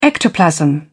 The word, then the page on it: ectoplasm: British English